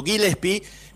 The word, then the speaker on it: Gillespie: así pronunciado Gillespie,